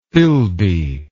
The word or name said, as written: Philby